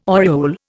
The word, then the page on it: oriole: Indian